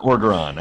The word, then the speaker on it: Orgeron: Orgeron? Orgeron. I never say it correctly. You always have to correct me.